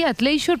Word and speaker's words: athleisure: Como digo, no asustarse, queridos oyentes, se pronuncia así, athleisure, pero en realidad, aunque yo estoy diciendo todo el rato chandalismo, no es correcto, ¿no, Rosa?